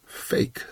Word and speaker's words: PHEIC: Und da gibt es eine wunderbare Abkürzung, nämlich PHEIC. Das Ganze spricht sich aus Fake.